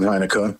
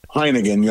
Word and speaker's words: Heinicke: And um, there's a lot of hype around Heinicke, which is, I believe, how you pronounce it, even though Leonard Williams is the one who actually called him Heineken yesterday.